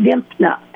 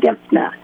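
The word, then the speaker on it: Dymphna: Yes, probably the best known saint is Saint Dimphna, and I will spell that. That's D-Y-M-P-H-N-A, Saint Dimphna.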